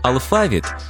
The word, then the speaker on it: алфавіт: Наголос у слові алфавіт на другому складі основний у нашій мові, бо збігається із грецьким алфавітон, від якого походить українська лексема.